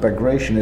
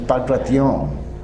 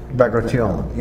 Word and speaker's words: Bagration: And the commander of Second West Army, usually pronounced Bagration, is, and this is actually not quite right, it's not Bagration, it's Bagration. – Bagration. – The accent I get is in the last syllable.